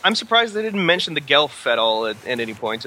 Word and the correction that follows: Geth: Or the Gelf, or am I pronouncing that right? I forget already. – The Geth.